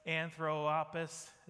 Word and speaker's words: Anthropos: It's spelled A-N-T-H-R-O-P-O-S, Anthropos. Is that good? Not like anthrax, but Anthropos, okay?